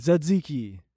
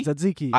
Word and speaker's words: tzatziki: What is zinziki? – Tzatziki. – Satsiki? – Tzatziki. – I know how to pronounce it.